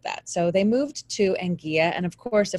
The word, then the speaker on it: Anguilla: So him and his wife, Barbara, moved to Anguilla, the island of, is it Anguilla or Anguilla? It's Anguilla.